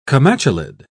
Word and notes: comatulid: British English

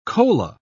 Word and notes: kola: British English

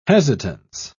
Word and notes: hesitance: British English

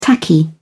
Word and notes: tacky: British English

